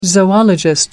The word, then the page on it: zoologist: American English